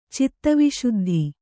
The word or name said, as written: ಚಿತ್ತವಿಶುದ್ಧಿ